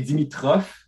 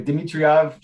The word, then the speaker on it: Dimitroff: Euh, c'est Dimitrov. – Dimitrov. mais, ben, ben, Dimitriov, j'ai eu ça souvent.